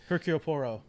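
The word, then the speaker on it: Hercule Poirot: Yeah, because I can never pronounce that guy's name. The Hercule Poro, like Hercule Poro. – That's it.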